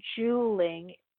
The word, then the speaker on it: Juuling: Juuling, spelled J-U-U-L, and it's pronounced Juuling, J-U-L-L is the name of the product,